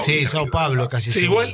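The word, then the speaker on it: São Paulo: Sao Paulo.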